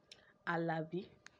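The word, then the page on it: Alabi: Yoruba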